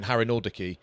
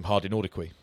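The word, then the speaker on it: Harinordoquy: I remember doing Harry Nordicke, Imanol Harry Nordicke, and I knew how to say his name, but the guy I was commentating with called him Hardy Nordicke.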